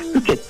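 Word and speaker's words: 深井: いや、そしてね、おふけ焼きっていうんですか、というのは、これはどういったものなんでしょう。 – このおふけ焼きとはですね、名古屋城内で焼かれてたですね、いわゆる殿様のお庭焼きってものでですね、で、ふけってのは深いって字に井戸の井って書いて、これふけって読むんですけど、これはですね、もともとの意味は沼地とか湿地帯のことを指す言葉なんですね。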